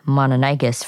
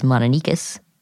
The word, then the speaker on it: Mononychus: And now for our dinosaur of the day, Mononychus, which Garrett mentioned earlier, we have been pronouncing Mononychus for a while, but apparently more people pronounce it as Mononychus. – Yeah, it looks like Mononychus when you read it because there's a Y before the cuss.